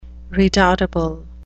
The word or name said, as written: redoubtable